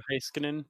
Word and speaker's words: Heiskanen: or is it Heiskanen? Is that how you say it properly? – Yeah, Miro Heiskanen.